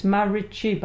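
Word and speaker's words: Marici: Yeah, it's Marichi, by the way. Yeah? – Is Marichi, la, pronunciación. Yeah.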